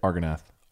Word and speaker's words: Argonath: I always recall standing in my driveway and pretending to be one of the statues of Argonath. How do you say that? – Argonath? – Yep.